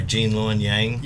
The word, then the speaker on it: Gene Luen Yang: Gene Luen Yang.